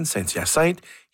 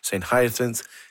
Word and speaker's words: Saint-Hyacinthe: The guard with whom I checked and asked my destination, I told him using the French pronunciation, Saint-Hyacinthe. He didn't understand. I finally reverted to the West Island English pronunciation, Saint-Hyacinthe, and says that he understood.